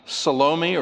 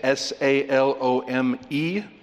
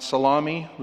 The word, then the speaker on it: Salome: But as we compare Scripture with Scripture, it's likely that she is um, Salome or Salome, depending on how you say it S A L O M E not salami, but Salome.